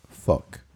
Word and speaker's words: phoque: For those not following along, the word in French for seal is spelled P-H-O-Q-U-E, and it is pronounced fuck.